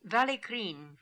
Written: Vallikrin

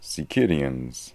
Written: Sikirians

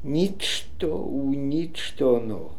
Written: nichto oonichtono

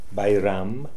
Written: Bairam

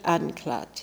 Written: Anklad

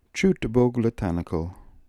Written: chootboglitanical

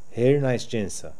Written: Hernasdjinsa

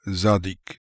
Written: Zadik

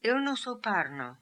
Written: Ilnosoparno

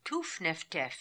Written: Toof Nef Tef

